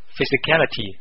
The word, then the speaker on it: physicality: 注意啊，读音还是有点不同的，一个就是 physical，另外一个就是 physicality。